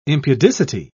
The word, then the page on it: impudicity: British English